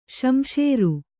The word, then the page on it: ಸಂಸಾರಿ: samsāri; Kannada